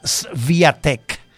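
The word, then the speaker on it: Świątek: Bueno, la número uno del mundo, tenis femenino, Iga Swiatek. Recuerde que se escribe con W, pero esa W suena como una B. Swiatek. S-W-I-A-T-K.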